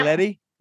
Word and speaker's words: Galletti: Galletti. It rhymes with spaghetti.